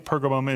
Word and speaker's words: Pergamum: Some transcripts say Pergamus, but I think Pergamum is the better way to pronounce the church and the city.